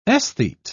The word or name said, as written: esthete